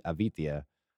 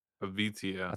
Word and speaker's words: Avitia: I guess the accent's on the V, Avitia. – Avitia. – That's how he said it anyways.